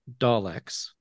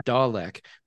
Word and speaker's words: Dalek: Okay, to be fair, Tom, the Brits invented Daleks and mispronounce the word. They say Dalek, but if you look how it's spelled, Dalek. It's a Dalek.